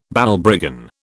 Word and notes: Balbriggan: British English

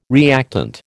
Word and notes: reactant: American English

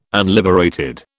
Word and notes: unliberated: British English

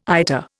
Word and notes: IATA: Indian

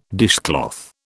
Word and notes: dishcloth: British English

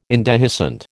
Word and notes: indehiscent: American English

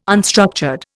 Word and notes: unstructured: Indian

